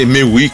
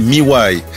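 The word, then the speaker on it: Mewhy: Moi, j'avais pensé que c'est, on disait mais oui, comme mais oui, quoi. Mais non, ça s'écrit, ça se dit Miwai.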